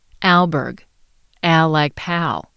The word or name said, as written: alburgh